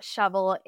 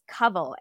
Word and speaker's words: Kawal: It's not shovel, it's covel.